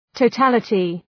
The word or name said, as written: totality